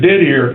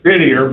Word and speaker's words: Didier: and his name is Didier, but you know, I guess they give it the French pronunciation.